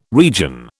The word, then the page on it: region: British English